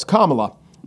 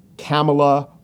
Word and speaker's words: Kamala: It's, of course, Kamala. But you'll hear people say Kamala or Camilla, maybe not as much as before she ran, but that was prevalent. You know, if she's the VP pick, fewer and fewer people will say anything but Kamala.